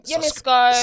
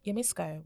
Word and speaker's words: Yemisco: I'm Yemisco. – Subscription. – Okay, on a serious note, my name's Ola Yemi, and you can find me on Instagram on Yemisco,